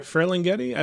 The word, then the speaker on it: Ferlinghetti: Do you know if I pronounced that name right? – Ferlinghetti? – I believe so. – You got it, yeah.